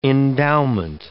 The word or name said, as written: endowment